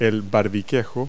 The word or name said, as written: bobstay